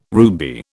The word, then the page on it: ruby: British English